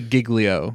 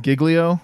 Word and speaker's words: Giglio: Is he Giglio? – I would say Giglio. – Giglio? Okay. – Yes. That sounds right to me as an Italian-American.